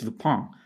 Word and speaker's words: Lupin: And most notably, recently, she worked on the Netflix show Lupin, or if you're French or want to pronounce it correctly, it's Lupin.